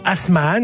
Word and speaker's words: Asmahan: Voilà, c'est la chanteuse syrienne Asmahan, je ne sais pas si je prononce bien. – Tout à fait, non, c'est bien ça.